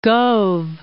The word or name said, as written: Gove